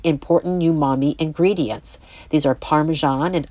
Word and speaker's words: umami: The first book is Umami Bomb, 75 Vegetarian Recipes That Explode with Flavor, 2019 by Raquel Pelzel. Umami, pronounced umami, is often referred to as the fifth taste because the richly satisfying sensation defies categories of sweet, sour, salty, or bitter.